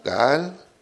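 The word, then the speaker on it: Gaal (גאל): La primera es Gaal con doble A, Gaal.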